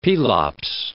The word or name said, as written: Pelops